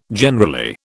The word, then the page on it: generally: British English